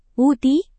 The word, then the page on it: ui: indonesian